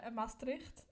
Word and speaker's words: Maastricht: No, Maastricht and Groningen.